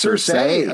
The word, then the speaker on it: Cersei: I thought it was Cersei, but it's Cersei, according to the show.